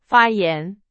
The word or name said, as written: 发言